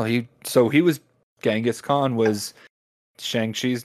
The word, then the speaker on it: Genghis Khan: Genghis Khan.